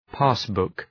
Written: passbook

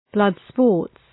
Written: blood sports

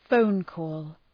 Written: phone call

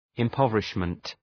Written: impoverishment

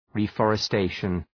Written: reforestation

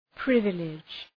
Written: privilege

